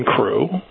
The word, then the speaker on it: Croo: Harvest Crow Robotics, C-R-O-O. What's the impact of that? Crew. What is it? Harvest, how do you pronounce it? – Crew. Harvest Crew. – Harvest Crew. As in C-R-E-W, in a manner of speaking.